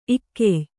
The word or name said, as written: ಈಗ್ಯೆ